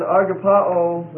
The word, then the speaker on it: agapao: You have agape, A-G-A-P-E, long E. That's a noun. Okay, thank you. And then agapao would be the verb, A-G-A-P-A-O. And that's a long O.